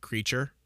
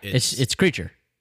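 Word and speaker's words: Kreacher: It's like Creature. Like, it's not spelled like a creature. – Yeah, it's Creature.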